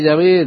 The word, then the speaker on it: Yahvé: Hay un debate teológico acerca de cuál sería la pronunciación correcta, si es Yahová o Yahvé o Jehová. Parece que Yahová es un tipo más reciente de pronunciación de la época, tal vez del siglo XVI, y que Yahvé, de hecho, es la correcta pronunciación del nombre de Dios.